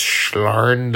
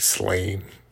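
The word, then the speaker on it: Sláine: And Simon Bisley's just like, You probably, if you know Simon Bisley, he came to prominence working for 2000 AD, drawing, I think it's pronounced Schlarn the Berserker, because it's, you know, Celtic, and nothing is pronounced as you think it would be. It looks like Slain, but I think I heard somewhere that it's Schlarn.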